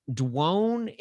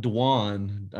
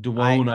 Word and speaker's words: Dwone: A guy by the name of Kenneth Dwone Hicks. I think I'm pronouncing that right. It's D-W-O-N-E. So Dwone? – Yeah, it would D-W-O-N-E. – Dwone? Dwone? – It's not Dwan. I've never Dwone? Heard Dwan.